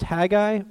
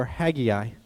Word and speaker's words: Haggai: Is it pronounced Haggai or Haggai? The answer is, it could be pronounced either way. I pronounce it Haggai, that's just how I do it, but it's very okay to pronounce it either way. I'm perfectly fine with either one of those.